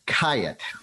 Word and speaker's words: Khayat: Mark Kayat. – Kayat. – Kayat. – Like a kayak, but with a T on the end. – Or like a Hyatt House with a K on it. – Oh, I like that. – K-H-A-Y-A-T.